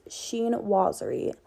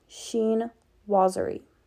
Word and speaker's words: Chinoiserie: Okay, and another pattern I want to talk about that I have been seeing all over is Sheen Wazzery. Okay, I know you're probably thinking, what the heck is that? Sheen Wazzery. It's spelled Chinoiserie. Sheen Wazzery.